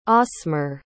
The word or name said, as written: osmar